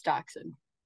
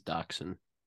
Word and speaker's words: dachshund: It's definitely deshunned is dachshund. – Okay, wait. – It's like it's supposed to be. – Pronunciation. Dachshund.